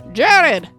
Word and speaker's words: Gerard: So now Jared, now it's spelled G-E-R-A-R-D, Jared.